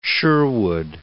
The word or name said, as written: Sherwood